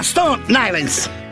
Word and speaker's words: Stone Island: Questi gym della Stone Islands. – Stone Islands. – Ma perché scrivono? Scrivono come si legge. – Si, si chiama così, Stone Island.